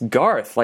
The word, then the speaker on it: Garth: It's spelled Garth. Do you say Gareth? – I said Gareth. I thought it was Gareth. – I think it's Garth, like Garth Brooks.